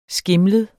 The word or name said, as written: skimlet